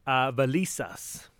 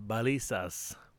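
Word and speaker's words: Valizas: Well, we're in this little place called Valisas, or the locals kind of pronounce it Valisas.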